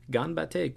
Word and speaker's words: ganbatte: G-A-N-B-A-T-T-E, which is ganbatte. Ganbatte, you know, do your best. That's an informal version of it. Or ganbatte kudasai, which is a formal version.